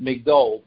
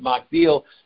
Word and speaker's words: Migdol: And in the chapters in Tehillim, even though even in this Haftorah, it's spelled Magdil, but we read it Migdol. But in Tehillim, we read it Magdil.